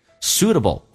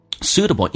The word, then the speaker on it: suitable: Suitable 指的是合适的，但有时候这个单词会反应不过来， suitable 英式发音叫 suitable，发 u，美式发音 s 加 u 发成 u 这个发音，而且 t 变成 d 变成 suitable，跟 suitable， suitable 还是有点差别。